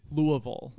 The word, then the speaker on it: Louisville: It's Louisville.